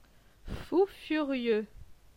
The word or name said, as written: Fou furieux